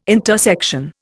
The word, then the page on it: intersection: Indian